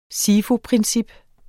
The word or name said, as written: SIFU-princip